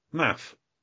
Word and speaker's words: Maph: And Math is spelled M-A-P-H, pronounced M-A-F-F, Math. I want to say Maths. So I'm British, but I'm going to do that. It's Math.